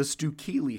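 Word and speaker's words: Stewkeley: Stuhlicki, that's how you pronounce it. The Stuhlicki family.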